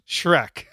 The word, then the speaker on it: Schreck: Spelled differently, but it's pronounced Schreck.